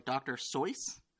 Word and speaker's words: Seuss: His last name, Dr. Seuss, is actually supposed to be pronounced like Rolls Royce, so like Dr. Soice.